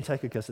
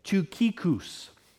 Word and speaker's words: Tychicus: I'm saying Tychicus. It's the totally wrong way to say his name. You're supposed to pronounce it this way, Tychicus.